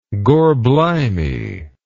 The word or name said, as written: gorblimey